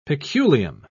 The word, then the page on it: peculium: British English